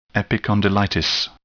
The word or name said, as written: epicondylitis